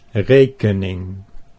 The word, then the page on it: rekening: Dutch